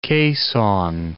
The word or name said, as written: Quezon City